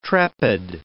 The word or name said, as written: trepid